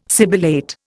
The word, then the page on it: sibilate: Indian